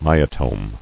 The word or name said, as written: Myotome